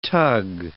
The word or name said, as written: tug